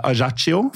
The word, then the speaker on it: Ajaccio: Ja, sånn I tilfelle folk lurte på om vi litt dårlige I språk, så det sånn vi uttaler Ajaccio.